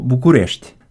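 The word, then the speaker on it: București: Это было что-то вроде Букурести ну, Букурешт, да, вот так оно произносится, а, да, по-русски Бухарест, а по-румынски Букурешт.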